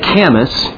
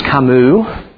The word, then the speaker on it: Camus: It looks like Camus, but it's Camus. So Jean-Paul Sartre and Albert Camus, these were most well-known existentialists who are not living anymore. They're not existentialists anymore. Camus said,